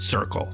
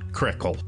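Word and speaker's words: Cyrkle: The name of the band, the recorded Red Rubber Ball, is pronounced Circle, not Crickle.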